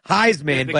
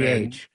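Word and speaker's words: Highsman: Heisman, but High is spelled H-I-G-H. Heisman.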